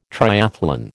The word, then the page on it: triathlon: American English